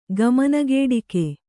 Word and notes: ಗಮನಗೇಡಿಕೆ: kannada

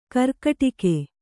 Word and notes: ಗೀರ್ಕಡಗ: Kannada; gīrkaḍaga

